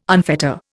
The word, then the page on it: unfetter: Indian